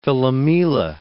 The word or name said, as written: Philomela